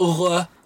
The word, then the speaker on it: oeuvre: Oeuvre.